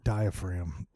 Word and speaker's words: diaphragm: Diaphragm. Got it. No problem. Aces. What do you mean there's a fucking G? There's no G. – That's a silent G.